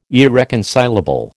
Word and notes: irreconcilable: American English

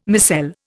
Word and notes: micelle: Indian

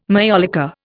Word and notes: majolica: Indian

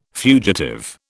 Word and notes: fugitive: British English

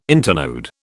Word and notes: internode: British English

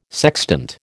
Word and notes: sextant: American English

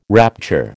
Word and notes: rapture: American English

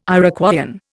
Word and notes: Iroquoian: Indian

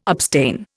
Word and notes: abstain: Indian

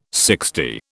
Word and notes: sixty: British English

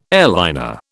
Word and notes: airliner: British English

